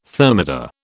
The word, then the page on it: thermidor: British English